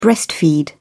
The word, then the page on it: breastfeed: British English